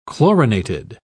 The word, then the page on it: chlorinated: British English